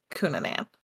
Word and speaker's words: Cunanan: So Andrew Philip Cunanan, and I hope I'm saying that right. It's C-U-N-A-N-A-N. – You are Cunanan.